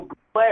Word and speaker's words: Bublé: How do you say his last name? – Bublé. – Michael Bublé or It's Bublé.